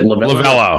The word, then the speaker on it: Lovullo: Torey Lovullo just spoke or did I say his name right? Is it Lovullo? Lovullo. Lovullo.